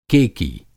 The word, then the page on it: केकी: Hindi